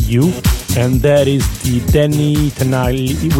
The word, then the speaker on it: Danny Tenaglia: Danny Tenaglia.